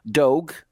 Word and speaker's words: Dogue: And he opened up this place called Dogue. D-O-G-U-E, rhymes with Vogue.